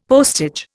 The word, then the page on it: postage: Indian